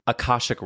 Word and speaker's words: Akashic: Can you tell me, first of all, you say that you offer Akashic Records.